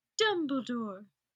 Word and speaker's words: Dumbledore: It's pronounced Dumbledore. That's what I said, Dumbledore. No, Dumbledore.